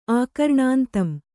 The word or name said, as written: ಆಕರ್ಣಾಂತಂ